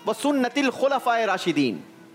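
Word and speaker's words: वसुन्नतुल खुलफाए राशिदीन (وسنة الخلفاء الراشدين): वैसे इसका प्रोनाउंसिएशन थोड़ा सा सही करना होगा, वसुन्नतुल खुलफाए राशिदीन, ठीक है?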